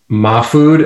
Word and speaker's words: Mahfood: Yes, yeah. Mafood is the correct pronunciation, by the way. But my family has kind of recognized Mafood as well, but Mafood is the proper rooted in Middle Eastern speak, I guess I would call it.